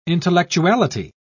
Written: intellectuality

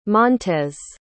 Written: montes

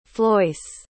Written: floyce

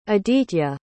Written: aditya

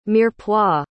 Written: mirepoix

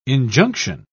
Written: injunction

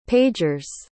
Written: pager's

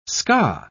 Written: ska

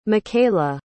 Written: mikaila

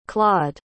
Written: clawed

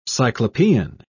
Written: cyclopean

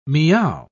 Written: meow

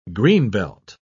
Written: greenbelt

